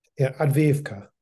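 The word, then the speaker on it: Avdiivka: This battle, I can't pronounce Avika. Please pronounce this one, the latest one, Advivka.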